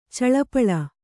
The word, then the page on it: ಚಲ್ಲಪಿಲ್ಲಿ: Kannada